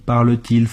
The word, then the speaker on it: parle-t-il: Votre ami parle-t-il français? – 您的朋友会讲法语吗？这里主语为第三人称单数 il，原形动词 parler 变为 parle，而且主谓倒装表疑问时，为了发音的方便，中间需要插入一个 de，并用连字符连起来。 – Votre ami parle-t-il français?